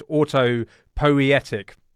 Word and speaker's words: autopoietic: now we have to be very careful how we pronounce this word. It's autopoietic. Is that right, Maxwell? – That's right.